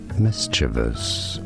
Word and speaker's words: mischievous: How do you say it correctly? Both British and American English pronunciations are similar, and it's rather easy when you know mischievous. – Mischievous. He went with mischievous.